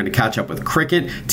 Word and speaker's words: Cricut: We're going to chat with a company called Cricut. It's spelled C-R-I-C-U-T, and their cutting machine called the Cricut Maker 3.